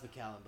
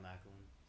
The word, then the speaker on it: Macallan: It's pronounced Macallan, but I call it Macklin.